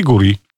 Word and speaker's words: Gudrid: Sabino, a my w imieniu Gudrid mamy tą taką dziwną literkę. Jak ją wymawiamy? – Ale jej nie wymawiamy, tylko po prostu ją piszemy, wiesz? – Czyli Guri. O, Guri, Hans Dottir.